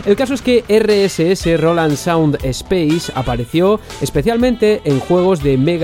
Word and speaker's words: Roland Sound Space: Otro ejemplo lo encontramos en el sistema de audio Roland Sound Space, que creo que antes lo dije mal, es Roland Sound Space o RSS,